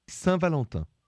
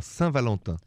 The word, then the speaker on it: Saint-Valentin: And in French, Saint-Valentin. – Oh, Saint-Valentin. Did I say that right? – You said it really well. Saint-Valentin.